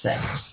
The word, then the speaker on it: seks: You see, Rembrandt did it with S-E-K-S in his paintings. So it doesn't matter. I guess that meant sex. You know, it's pronounced sex.